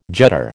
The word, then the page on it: judder: American English